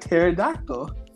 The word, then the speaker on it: pterodactyl: It's like pterodactyl. Why is there a P there? – Pterodactyl.